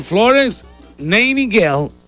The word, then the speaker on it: Nightingale: También hoy nace Florence Nightingale, así se pronuncia.